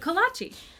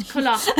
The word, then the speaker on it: kolache: It's not collage. It's not collage. – It's not collage. Collage. Collage. Okay, guys, whatever you want to pronounce it, they're amazing. K-O-L-A-C-H-E.